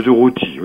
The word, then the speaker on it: Durruti: Alors, je vais mal prononcer, Buenaventura Durruti. – De Durruti, oui.